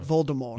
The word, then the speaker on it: Voldemort: Oh, like Voldemort.